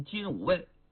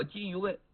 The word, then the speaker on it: 金吾卫: 金鱼卫很多人呢都读成金吾卫，其实应该叫金鱼卫，负责皇帝出行的时候前驱后殿，日夜巡查，止宿十司警戒之责呀。也就说呀，皇上身边的仪仗队、羽林军就差不多少。到了隋炀帝的时候，把金鱼卫就改为左右后卫。